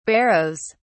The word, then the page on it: barrows: British English